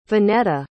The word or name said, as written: vanetta